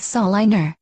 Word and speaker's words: Salina: Salina, Kansas, population 50,000. – No, you pronounced it incorrectly. The correct pronunciation is Saliner.